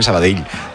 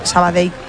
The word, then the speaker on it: Sabadell: El cachondo de Kiki Lesa me dice, pronuncias muy bien Sabadell. Dice, mis respetos. Sabadell. – A mí me lo decían mucho cuando estaba en Primera Federación, porque yo también decía Sabadell, que es como se dice.